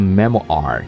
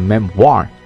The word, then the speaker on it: memoir: 它不读成 memoir 啊，读成 memoir 啊，因为它是来自于法语的一个词汇啊。